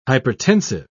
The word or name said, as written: hypertensive